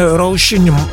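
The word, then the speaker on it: Róisín: Μου γράφει για την σωστή προφορά της Ρόζιν Μέρφυ που είναι Ρόζιν πάχιλε, πολύ παχύλε το σι.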